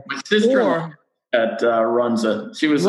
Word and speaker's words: Runza: Runza, yeah.